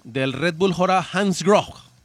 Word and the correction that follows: Bora Hansgrohe: Red Bull Bora Hansgrohe.